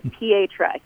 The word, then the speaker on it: Pietra: So Pietra, it comes from the root word Italian, which means rock. And it's often pronounced, you know, Pietra, Petra, Peter. And so everyone kind of has their own twist on it. But Pietra, yes, you are getting that correct.